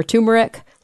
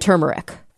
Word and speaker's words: turmeric: But yeah, it looks like the accepted pronunciations that I found were turmeric, like T-O-O, even though it's spelled with an R, and turmeric.